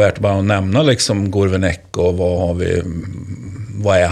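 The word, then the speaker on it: Gourvennec: Gourvenec. – Gourvenec, det är så enkelt. Gourvenec.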